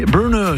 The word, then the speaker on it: Burna: Ici Normandie, et alors ça s'écrit Burna, mais ça se dit Burner, j'ai demandé, un peu comme Bernard en anglais, Burner, voilà, mémotechnique, c'est pour essayer de m'en souvenir jusqu'à la fin de l'événement.